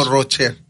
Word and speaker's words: Rocher: Pero en francés sí se pronuncia parecido al italiano, C-H-E. – Sí. – Rocher. – Rocher. – O, sí, ¿verdad? – No, Rocher. – Rocher. – Rocher en ese caso.